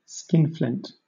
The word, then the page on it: skinflint: British English